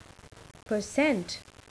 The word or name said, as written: percent